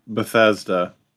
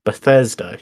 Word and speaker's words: Bethesda: Bethesda. – Bethesda, yeah.